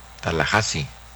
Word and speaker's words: Tallahassee: Tallahassee, Florida, se pronuncia Tallahassee, no Tallahassee.